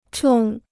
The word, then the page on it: 冲: chòng